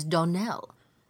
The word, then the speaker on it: Donnell: "Clarice Almira told me that you pronounced our name Donnell. Now, Miss Shirley, the correct pronunciation of our name is Donnell. Accent on the last syllable.